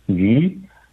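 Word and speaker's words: yu (鱼): Because fish, you know, in Chinese, it pronounced the pronunciation is yu, and it's the same pronunciation as leftover, surplus, and abundance.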